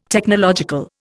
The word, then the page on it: Technological: Indian